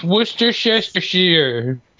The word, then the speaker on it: Worcestershire: You think it's worst of Shire? – Horse sisters. – Something like that. – It's Worcestershire. – Shit. – That's exactly just like that. That's how you pronounce it. It's perfect.